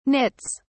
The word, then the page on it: nits: British English